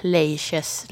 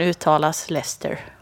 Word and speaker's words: Leicester: Stavas ju så här Leiches, Leicester. – Som staden. Men uttalas Leicester.